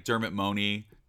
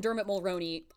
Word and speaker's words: Dermot Mulroney: Dermot Mulroney. I knew I was going to say it wrong. I keep saying his name wrong, like Dermot Mooney.